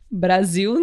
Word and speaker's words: Brasil: Brasil, né? Aqui no Brasil se escreve com a letra S, ah, né? e tem verdade. o som de Z.